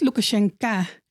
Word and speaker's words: Lukashenko: Hij heet Lukashenko. En je schrijft zijn naam met een O. Ja, in het Cyrillisch natuurlijk. Maar je spreekt onbeklemtoonde O's uit als een A. Dus deze man die spreekt zelf Russisch. En hij noemt zichzelf dus ook Lukashenko.